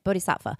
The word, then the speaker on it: Bodhisattva: And Kuan Yin is the Bodhisattva. That's how you say it. Bodhisattva.